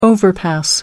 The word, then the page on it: overpass: American English